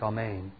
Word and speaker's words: Amen: That's how you pronounce it in Greek, Amen.